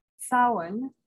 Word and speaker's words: Samhain: So the pronunciation is pronounced Samhain, like S-O-W, like a mama pig.